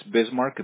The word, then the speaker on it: Bismark: It's Bismarck, BISMARK.